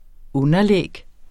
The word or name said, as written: underlæg